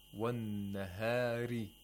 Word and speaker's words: والنهار: والنهاري. Ri. We don't say ray. Ray. Ri. Okay, so make sure you pronounce the ra with tarqiq, or the empty mouth, practically.